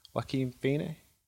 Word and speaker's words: Joaquin Phoenix: Stars Joaquin Phoenix. – I don't think anyone can actually say his name properly. – No, I think that is how you pronounce his name.